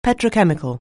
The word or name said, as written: petrochemical